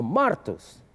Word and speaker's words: martus: Or martus. The accent is the first syllable.